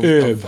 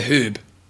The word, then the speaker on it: herb: Of, is it, how do you say herb? – No, I say herb. – Not say herb. Herb. It's got an H there. – Yeah, where's it herb? Is it a silent H?